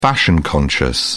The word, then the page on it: fashion conscious: British English